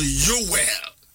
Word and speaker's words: Yoel: Hebrew for number pronounced Yoel. And I say Yoel.